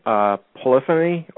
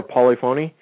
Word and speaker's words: polyphony: I've heard it pronounced two different ways: polyphony or polyphony.